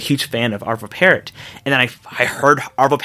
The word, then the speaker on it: Pärt: Yeah, Pärt, yeah. – His name is spelled P-A-R-T, but there's the little double dots above the A.